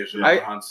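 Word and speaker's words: Djimon Hounsou: Because Jimon Hansu, that's how you say his name? – Yeah, Jimon Hansu.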